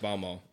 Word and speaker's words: Baltimore: It's pronounced Balmo.